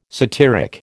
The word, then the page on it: satyric: American English